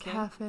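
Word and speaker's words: Cuffe: Rita Skeeter, Barnabas Cafe, I honestly don't know how to pronounce his Cafe.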